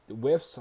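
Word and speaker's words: Wirfs: But I'm telling you, if you can get Diggs, if Tristan Wolfs, the Whiffs, I think is how you say his last name, the offensive lineman out of Iowa, I think you go that route.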